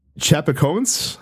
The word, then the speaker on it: Chapecoense: um, a plane that was carrying uh 19 members of uh Brazilian soccer team, and I'm gonna butcher the pronunciation. Of this uh Chapecoense, I think is how it's pronounced. – Sounds um, right.